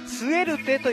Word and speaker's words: SUERTE: 新しいアーティストスエルテというアーティストでございます。スペルは SUERTE スエルテ。